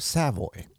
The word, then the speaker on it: Savoy: Savoy or Savoy? – Well, either way. – Okay. – I pronounce it Savoy. Savoy. – That's a very severe way to say Savoy.